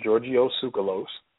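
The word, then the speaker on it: Giorgio Tsoukalos: Now, I believe his name is pronounced Georgios Tsoukalos. He is a proponent of the ancient astronaut theory, and he's also the producer of Ancient Aliens, I believe it is.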